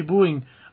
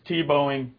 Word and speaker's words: Tebowing: And you do have tons of stuff to tell us about the Tibouing. Um, or would you say Tibouing or Tibouing? No, it's Tebowing. – Tebowing.